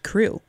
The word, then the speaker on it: CHRU: You're listening to WXYC Chapel Hill, and we'd like you to know about the Carolina Harm Reduction Union, known as CHRU, pronounced CRU, which is a student-led program that provides life-saving harm reduction resources to the public of Chapel Hill.